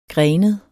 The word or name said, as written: grenet